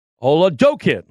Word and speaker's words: Oladokun: Chris Oladokun.